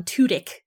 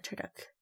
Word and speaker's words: Tudyk: So it's a combination of what, because I always used to say Alan Tudyk, and you said Tudyk.